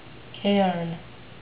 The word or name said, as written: cairn